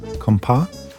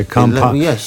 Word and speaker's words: compas: And now I always pronounce this wrong, compas? – You said it correctly. – Okay, compas.